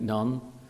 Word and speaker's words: Nun: Joshua, the son of Nun. Of course, Nun is a Hebrew word. It doesn't mean the word. It's only pronounced as we think, Nun.